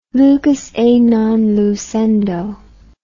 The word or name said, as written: lucus a non lucendo